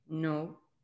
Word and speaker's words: non: Non. – So the N here is silent. Okay, so it's like non.